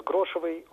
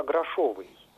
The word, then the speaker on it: грошовый: А мой вопрос про другое слово из этой песни, там поется про презревших грошевой уют, и некоторые исполнители произносят грошевый уют, вот при том, что литературная норма грошовый. А остальные варианты, грошевый и грошевой, они, в общем-то, не допускаются даже в качестве разговорных.